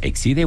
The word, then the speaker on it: Exide: ¿Se acuerda que había unas baterías Exide o Exide? – Exide o Exide. – Como quiera. Hormuz. Dentro se escribe Exide, se pronuncia Exide.